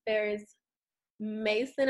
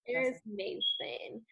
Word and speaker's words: Ferris Mason: And today we have a very, very special guest, Ferris Mason. Am I saying your name right, Ferris? – Yes, Ferris Mason. Yep. – Ferris Mason.